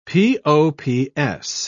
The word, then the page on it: pops: British English